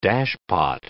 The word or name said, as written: dashpot